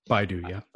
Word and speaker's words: Baidu: Oh yeah, then like Baidu is at 9. Baidu, is that even how you say it? I don't even know. – Baidu, yeah.